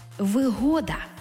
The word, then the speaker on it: вигода: це вигода з упевненим наголосом на другий склад. Вигода.